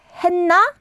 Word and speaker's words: 했나: Again, that is spelled as 쌍시옷, the double 시옷 받침. – The same pattern. – But you pronounce it as 했나 봐.